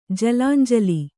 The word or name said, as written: ಜಲಾಂಜಲಿ